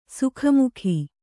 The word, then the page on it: ಸಾಕುಮಗ: sāku maga